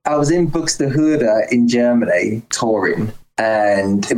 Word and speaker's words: Buxtehude: Buxtehude. Yeah, you said it right.